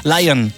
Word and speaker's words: Lyon: Ça s'écrit avec un Y. – Lion, comme la gare de Lyon.